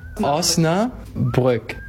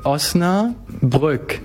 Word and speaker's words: Osnabrück: Osnabrück. – Sorry? – You know, like Osnabrück. – Brook. – Brook, yeah. – Brook. – And now together. Osnabrück.